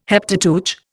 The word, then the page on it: Heptateuch: Indian